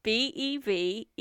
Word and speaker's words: Bevegnati: That's how it's spelt. Like lasagna. – I can't imagine anything spelt like Gnatti. – Like lasagna. – What? – Like, so his name was Giunta Bevegnati. Bevegnati. Like in lasagna.